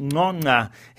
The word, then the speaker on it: Ngcongca: His name Ngcongca is very difficult to pronounce. It's spelt N-G-C-O. Ngcca, pronounced Ngcongca. His club, Troyes, are bottom of the French League One with no wins in their 19 games.